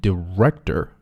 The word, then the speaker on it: director: And I called up our director and I said, Director, 注意要 r 它的发音 director.